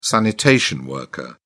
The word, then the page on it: sanitation worker: British English